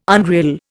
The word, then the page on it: unreal: Indian